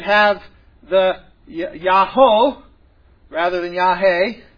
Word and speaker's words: Yahweh: It is a four letter word, yod, hey, vod, hey, and those four letters we think would most properly be pronounced Yahweh.